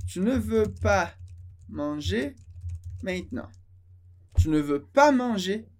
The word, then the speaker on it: maintenant: Maintenant. Maintenant, palavra muito importante, significa agora. Maintenant. Maintenant. Vou escrever aqui como a gente pronuncia ela. Maintenant. Tem várias letras, mas a gente pronuncia maintenant. Maintenant.